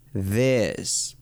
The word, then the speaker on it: this: Por ejemplo, this significa esto, pero se escribe T-H-I-S. T-H-I-S. Y la TH en inglés se pronuncia this, con la lengua afuera. Y digo con la lengua afuera porque muchas veces se confunden con la D latina. Muchas personas dicen this, this, this, pero no es una D como en español.